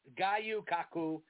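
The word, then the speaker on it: Gyu-Kaku: Gayu Kaku. It's spelled G-Y-U dash K-A-K-U.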